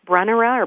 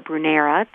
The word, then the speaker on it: Brunnera: Brunnera or Brunera, depending on your pronunciation, heart-shaped leaves.